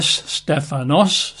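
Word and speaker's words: stephanos (στέφανος): You spell that S-T-E-P-H-A-N-O-S, stephanos, stephanos.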